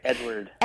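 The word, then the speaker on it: Eadweard: He is the founder of Motion 58 and director of the Vith film Eadward. I hope I am pronouncing that right. Hi, Kyle. – Hey, how's it going? Yeah, you said it right, Eadward.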